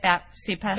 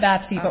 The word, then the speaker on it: BAPCPA: So is that how you say it, BAPCPA? – BAPCPA, okay, or BAPCPA.